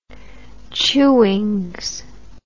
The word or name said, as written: Chewings